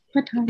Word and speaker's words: Pathaan: Did I say that right? – Pathaan. – Okay. I was like, don't evoke my Indian card because I can't say it. I don't know what it is. Whenever there's a word with P in it, I say it wrong. – Oh, really? Mm-hmm. I thought it would be the TH for you. – Oh, it might be the TA. I don't know. Anyways, I said it right.